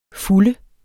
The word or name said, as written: fulde